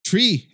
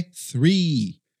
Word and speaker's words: three: Para você pronunciar o TH, aí você vai lá e solta um tree. É assim que você fala? Você solta um tree? Não, esse aqui é three.